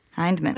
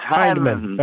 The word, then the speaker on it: Hindman: Hindman. – It's Hindman.